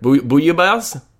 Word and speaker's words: bouillabaisse: If you ever want to bring it up on a podcast and don't want to look like a fool, it's pronounced bouillabaisse.